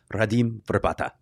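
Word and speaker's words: Radim Vrbata: And they'll sound nothing like anything we ever say, including his own name, which sounds like this, Redeem Verbata.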